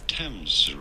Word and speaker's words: Thames: We are looking at how to pronounce Thames River. – The Thames River. – Very straightforward. Thames River. – Thames River. – Thames. That's not how it's spelled.